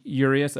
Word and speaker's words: Urias: I don't know how to pronounce his name, so maybe you can help me with that. – And Urias is correct. – Urias, okay.